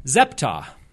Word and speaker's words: Zeptah: The name is Zeptah. That's how I'm pronouncing it. Z-E-P-T-A-H is how it's spelled in the Kirtland era manuscripts, Zeptah. That is unquestionably a dead ringer, absolutely attested, 100% Egyptian name, Zeptah.